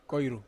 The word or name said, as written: koiru